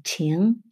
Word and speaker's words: 情: 정, 칭, 정. 우리 초코파이에 써진 한자 아세요? 여러분들 초코파이 나중에 그냥 드시지 말고 박스 한번 보세요. 거기 정자가 써있어 초코파이 정. 그걸 칭이라고 읽어요. 중국어로는.